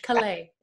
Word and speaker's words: Calais: Or Calais. I keep saying it wrong. We got to go with the French. He's the French. Calais. It sounds so much cooler.